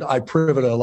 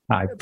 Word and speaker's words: iPrivata: And we pronounce it IPrivata, like IP. – IPrivata. – Private IP. – Got it. – It's my IP.